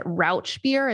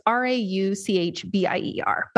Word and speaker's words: Rauchbier: I pronounce it Rock beer. I've heard other people pronounce it Rauch beer. It's Rauchbier, but this literally translates to smoked beer, and that's absolutely what it is.